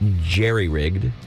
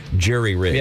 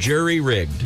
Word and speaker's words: jury-rigged: 74% of Americans say jerry-rigged. 26% say jury-rigged. The correct term, the original term, is jury-rigged. – Yeah. – What? – Jury-rigged.